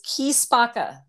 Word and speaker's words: Chi Spacca: it is Chi Spacca, but it's spelled C H I.